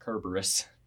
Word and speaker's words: Cerberus: That's why nobody remembers that Cerberus is technically pronounced Kerberus.